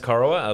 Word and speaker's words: Corowa: Corowa, is it? That's how you pronounce it? – It is pronounced Corowa, a little town nestled on the banks of the mighty Murray River, down on the New South Wales-Victorian border.